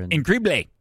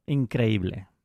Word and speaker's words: increíble: In Grible. – The leading scorer. – And in Grible. – In the, is that how you say it? – Increíble.